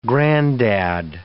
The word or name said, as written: grandad